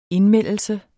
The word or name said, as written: indmeldelse